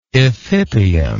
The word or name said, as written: ephippium